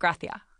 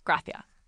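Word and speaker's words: Gràcia: But for example, there's a neighborhood there spelled Gracia, and we would call that Gracia, right? We would pronounce that Gracia. They pronounce it Gracia. – Gracia. – But the TH is very short.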